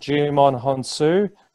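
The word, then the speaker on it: Djimon Hounsou: I think his name's pronounced Jumon Honsu.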